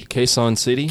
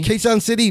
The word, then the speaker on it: Quezon City: Quezon City. – Quezon City, number one city in the Philippines.